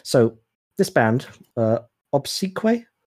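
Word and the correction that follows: Obsequiae: am I saying that right? – Obsequii. – Okay, that's cool. Okay, Obsequii.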